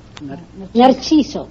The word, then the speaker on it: Narciso: How do you say it? – Narciso. – Narciso. Yes, that's pretty good.